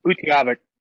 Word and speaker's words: Utqiagvik: Utqiagvik.